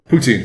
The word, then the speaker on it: poutine: How do you pronounce that chips you guys eat? Poutine? Poutine? – Poutine. – Is that how you... Poutine? – Yeah.